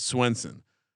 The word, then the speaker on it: Svensson: Svensson. – Yeah. – I went to school with a Svensson, although he pronounced it Swenson. Maybe that's the Jersey pronunciation.